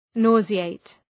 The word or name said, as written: nauseate